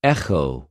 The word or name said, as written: ἐχω